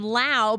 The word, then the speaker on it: Lowe: Because they're spelled the same way, but they're pronounced differently. They remember Brandon Lau because it rhymes with ow, and Brandon Lau is injured more.